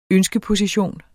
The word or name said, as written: ønskeposition